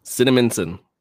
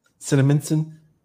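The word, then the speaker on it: Cinnaminson: How do you say that? Cinnamonson. Cinnamonson. Yep. – Yeah, that's how I would say it.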